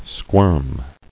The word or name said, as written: Squirming